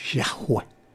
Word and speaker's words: Yahweh: YHWH, que se pronuncia Yahweh.